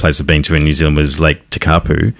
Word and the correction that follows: Tekapo: Yes. – Which, Tekapo. – Tekapu. Yep. Sorry. Can never pronounce it.